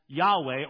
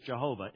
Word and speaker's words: Yahweh: And the problem is, because we don't render it as Yahweh, or as we slaughter it in English, Jehovah, it could not have been that. That's a three-syllable word. It's only a two-syllable word.